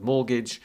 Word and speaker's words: mortgage: And you have to say, like, use the word mortgage. And then you check students know the spelling, because we've got silent T in there.